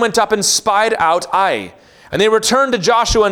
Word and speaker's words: Ai: If you grew up like me, you heard that pronounced Ai. It is a single syllable, Ai. If you want to call it the other thing, that's just fine too.